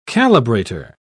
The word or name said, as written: calibrator